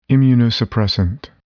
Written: immunosuppressant

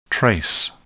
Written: trace

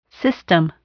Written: system

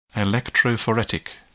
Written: electrophoretic

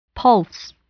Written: pulse

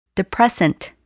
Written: depressant

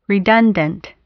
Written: redundant

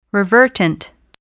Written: revertant

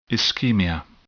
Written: ischemia

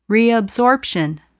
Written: reabsorption

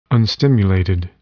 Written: unstimulated

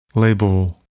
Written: labile

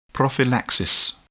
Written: prophylaxis

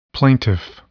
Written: plaintiff

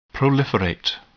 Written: proliferate

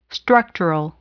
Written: structural

